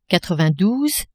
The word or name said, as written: quatre-vingt-douze